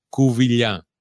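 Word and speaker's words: Covilhã: Covilha. It's because you don't have an accent. I mean, okay. – The way you read it, you probably don't know the sound of an A with the tilde over it.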